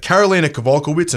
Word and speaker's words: Karolina Kowalkiewicz: And the next fight was the chicks going at it, Karolina Kowalkiewicz and Rose Namajunas. And how good was my Polish pronunciation? – That was very good.